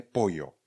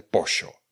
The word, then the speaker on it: pollo: V Mexiku se mluví mexicky, peruánsky, eh, Bolivia má svůj jazyk kečua třeba, Argentina má úplně specifické slova, jo například pojo, neboli kuře, pojo, píšeme p o l l o, tak se ve španělštině řekne pojo. A když budete v Argentíně, tak ho řeknete pošo.